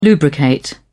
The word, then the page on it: lubricate: British English